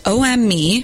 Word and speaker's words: OHMME: I'm DJing, and you've got coming up next OME, and it's spelt O-H. Hang on a second. O-H-M-M-E, like O-M-E is the band, but it's one word, and they're rad.